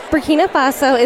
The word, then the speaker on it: Burkina Faso: And where is Burkina Faso? – Burkina Faso is in West Africa.